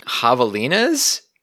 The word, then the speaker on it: javelinas: In the description of the shot, you mention javelinas. Is that how you pronounce it? – Javelinas.